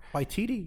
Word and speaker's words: Waititi: Waititi, yeah.